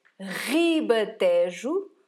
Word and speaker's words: Ribatejo: Pay attention to the R in Ribatejo. It's a strong R. Ribatejo, or you can also say Ribatejo, and Extremadura.